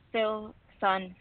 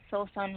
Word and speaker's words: Filson: My name is Filson. I'm calling from Columbus, Ohio. – Columbus, Ohio. Can you say your name one more time? I didn't quite catch it. – Filson. Filson, like Philip. Filson.